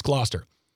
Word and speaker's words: Gloucester: I usually know how to say these names. Gloucester. It's Gloucester.